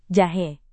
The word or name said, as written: jahe